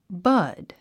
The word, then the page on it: bud: bʌ’d; バド